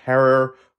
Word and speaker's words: Herer: That is indeed how she began working side by side with Jack Herer, Rhymes with Terror,